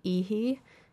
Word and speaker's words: Iji: Before Ace Martins, you heard the song Hard to Wait by Ihi, which is spelled I-J-I.